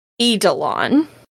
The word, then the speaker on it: Eidolon: I think the actual way you pronounce this word is Eidolon. Eidolon. I think it is pronounced Eidolon.